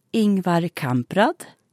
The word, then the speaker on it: Ingvar Kamprad: Ingvar Kamprad.